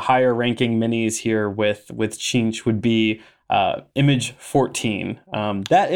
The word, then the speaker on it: Tzeentch: So let's move on to Cheench, which is spelled T-Z-E-E-N-T-C-H.